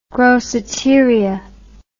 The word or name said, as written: groceteria